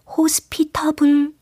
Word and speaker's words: hospitable: H O S P I T A B L-E. 발음은 어떨까요? hospitable 아니고 hospitable 이 맞습니다.